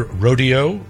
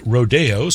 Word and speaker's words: Rodeo: It can be either Rodeo or Rodeo. Aaron Copland preferred Rodeo since that was the original Mexican term, but people have called it whatever they like ever since.